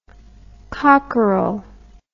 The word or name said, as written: cockerell